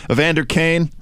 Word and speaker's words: Evander Kane: Evander Kane, right? Am I getting his name right? – Yeah, that's the guy.